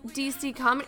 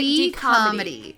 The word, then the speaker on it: D-Comedy: It's DC Comedy? Or how do you guys say D-Comedy? D-Comedy. Like DComs, like Disney Channel Original Movies.